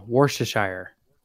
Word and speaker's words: Worcestershire: Worcestershire. It's like the T. You put less emphasis on the T. You just want to get through the shishir.